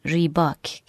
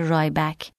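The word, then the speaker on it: Rybak: ریباک که همینجا بهتون بگم که در اون قسمت اسم این خواننده رو گفته بودم الکساندر رایبک که تلفظ صحیحی نیست و صحیحش ریباکه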